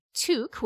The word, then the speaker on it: toque: It also could not pronounce the word toque, which is the way we say the word T-O-Q-U-E in this part of the world, which is the Canadian way to say like knitted head covering for winter.